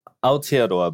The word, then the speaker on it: Aotearoa: This week on the show, we have the seabed mining campaigner at Greenpeace Altaora. Hello. – Kia ora. Hi, nice to meet you. – Yes, how did I? Okay, you say it correctly. – Aotearoa, but you did really well.